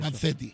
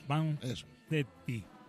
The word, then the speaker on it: Vanzetti: Vanzetti. – Vanzetti.